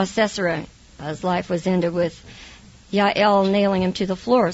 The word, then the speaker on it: Jael: Yael is the proper way to say her name.